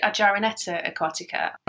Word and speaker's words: Argyroneta aquatica: Is it Argyroneta aquatica? – That's pretty close. We call it Argyroneta aquatica.